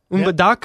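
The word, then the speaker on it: Mbandaka: Yesterday in the western city of, and I might not be saying this right, Umbadanka? – Close. – Umbadaka? Yep. – You know what? You nailed it.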